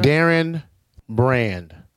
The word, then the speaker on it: Darren Brand: Darren Brand. Yeah, I say it like a politician.